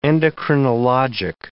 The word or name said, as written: endocrinologic